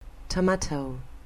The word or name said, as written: tomato